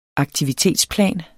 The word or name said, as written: aktivitetsplan